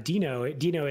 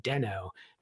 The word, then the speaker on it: Deno: Which is that Dino is the canonical pronunciation, but when it was originally announced by Ryan Dahl, who's the original author of Node.js and then created Dino, in the first talk that he introduced it, he said Deno, and then later decided that it would be better, like sort of pronounced as Dino. So it sort of switched.